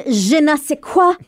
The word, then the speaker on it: je ne sais quoi: It's like, I got a certain je ne sais quoi about me.